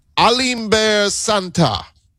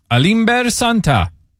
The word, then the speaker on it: Alimber Santa: The Astros have selected right-handed reliever, and I had to look up the pronunciation on this, and we can Americanize it if we want, but the pronunciation I got, the Latin pronunciation, Alimber Santa. – Alimber Santa.